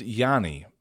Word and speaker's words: Ioannis: His name, it's pronounced Yanni, but it's spelled I-O-A-N-N-I-S, so it looks like Ionis.